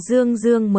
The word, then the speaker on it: Dương Dương: Tống Dương nhìn qua, Dương Dương, Quý Dạng sửa lại phát âm của anh, là Dương Dương mới đúng. Tiếng thứ nhất đọc thanh 2, tiếng thứ hai đọc thanh 4.